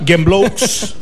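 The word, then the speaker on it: Gembloux: Sagibert de Gembló. – Assoliment excel·lent. – Oh, un aplaudiment, per favor. – Doncs aquest senyor, en Sagibert, parla de Gembló. Gembló, Gembló. Gemblous. Per als que pronuncien bé, parla de terratrèmols, no?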